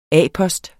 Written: A-post

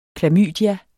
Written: Chlamydia

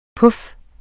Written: puf

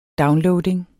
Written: downloading

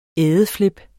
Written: ædeflip

